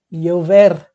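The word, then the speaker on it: llover: Aí está, chover que é com dois L's inicial, e na Espanha a gente pronuncia ia, iover, né? E, e no espanhol da, da América Latina já muda muito esse sotaque. Mas, bom, será que vai iover É muito parecido, gente, muito fácil praticar esse, né?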